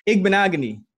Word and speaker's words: Igbinoghene: Igbinoghene. You just got to kind of let it slide off.